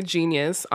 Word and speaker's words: Jeen-yuhs: I have some questions for you after the show about that, but beforehand, we're going to be discussing the new documentary Genius on Netflix. It's spelled J-E-E-N-Y-U-H-S, and it's all about Kanye.